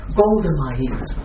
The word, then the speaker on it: Golda Meir: And I didn't know, I had no idea that, Gilda Meir, Golda Meir, pronounced wrong, who was head of Israel during the 1973 war.